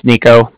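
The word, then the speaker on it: Niko: Like I said, my name's Nico. That's N-I-K-O. And then the Bank of Cardiff is spelled C-A-R-D-I-F-F as in Frank.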